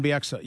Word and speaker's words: Bieksa: Kevin Bieksa, yeah.